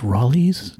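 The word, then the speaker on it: Rollies: You'll be talking about it, then you go, listeners, I just want you to know that it's pronounced Raleigh's. That's R-O-L-L-I-E-S. That's Raleigh's in Lowell.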